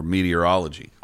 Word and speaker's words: meteorology: The pronunciation of this word is meatier than many of us think. Never forget that this word is built upon meteor, which makes all three syllables, initial syllables, mandatory. It's simple. Meteorology.